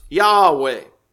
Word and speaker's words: Yahweh: And some people today think that is pronounced Yahweh. That seems to be the best pronunciation that we have of it.